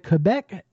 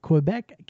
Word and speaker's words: Quebec: All right, now next up here, we've got a pop punk band out of Quebec City, Quebec, Canada. And I should pronounce, I think they really say it Quebec rather than Quebec, like us Americans do all the time.